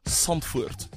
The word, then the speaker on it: Zandvoort: They're headed to Zandvoort for the... Zandvoort, apparently is how you say it.